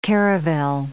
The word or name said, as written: caravel